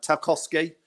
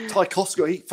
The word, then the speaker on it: Tarkowski: Just, I mean, briefly, there's things like Tawowski, I can never say it right, Tarkowski. – Tarkowski from Burnley for 50 million.